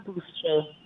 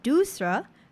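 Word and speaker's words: doosra: Yeah, the term Dusra.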